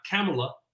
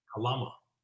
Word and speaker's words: Kalama: Yeah, it's pronounced how it's spelled, right? It's not Kamala. It's Kalama.